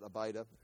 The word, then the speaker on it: Abida: The sons of Midian were Ephah, Epher, Hanok, Abida, that's how you pronounce it, Abida, there you go, Abida, and Eldiah.